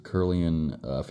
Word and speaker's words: Kirlian: It was called Kirlian photography, K-I-R-L-I-A-N. Am I saying that right? Yep. Kirlian.